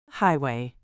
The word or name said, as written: highway